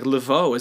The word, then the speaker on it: Laveau: I think it's how you say it. L-A-V-E-A-U. Laveau.